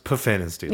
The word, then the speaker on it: Pfannenstiel: So the lovely Pfannenstiel that nobody can spell is that so for me, I believe it's pronounced Puffanisty.